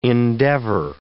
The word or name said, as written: endeavour